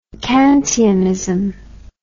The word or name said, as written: kantianism